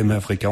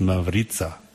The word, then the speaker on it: Mavrica: Et après, Mavrika, on peut expliquer à nouveau pour ceux qui ne savent toujours pas. Normalement, ça se prononce Mavrica, parce que c'est en slovène, et ça veut dire arc-en-ciel, tout simplement, comme la radio qui nous accueille depuis plus d'une décennie, 13 ans exactement.